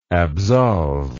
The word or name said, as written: absolver